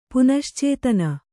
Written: ಪುನಶ್ಚೇತನ

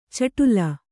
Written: ಚಿಟ್ಲಿ

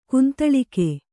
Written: ಗೊಂದಲಿಗ

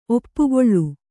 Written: ಒಪ್ಪುಗೊಳು

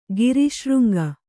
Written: ಗಿರಿಶೃಂಗ

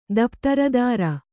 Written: ದಪ್ತರದಾರ

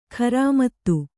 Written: ಖರಾಮತ್ತು

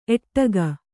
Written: ಏಡೂಕ

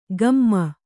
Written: ಕಮ್ಮ

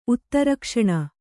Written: ಉತ್ತರಕ್ಷಣ

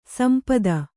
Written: ಸಂಪತ್ತಿ

